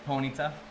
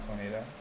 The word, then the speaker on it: Ponyta: How do you say this, guys? – Ponyta. – Ponyta. – I mean, how is it spelled in Zelda? – Eponia, or Epona or something like that. – It's not Ponita.